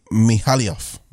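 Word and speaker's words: Mihailoff: Ari Mayhill. Oh, my God. How do you say that? How do you say that? I'm so sorry. Mihailov? – Okay, R.A. Mihailov.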